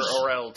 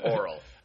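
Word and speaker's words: Orell: I prefer Orel to Oral.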